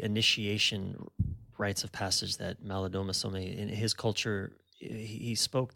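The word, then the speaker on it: Malidoma Somé: And I'm struck by something that one of your friends said, Maladoma Somme. Did I pronounce his name right? – Yes. Mm-hmm.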